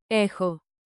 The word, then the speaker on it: Έχω: Pronounce chi like a soft kh. Forms with rhythm. Clap lightly and say it with me. Rhythm makes memory sticky. – Έχω.